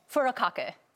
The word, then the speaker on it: furikake: How do you say it? – Furikake. – Furikake? – Furikake.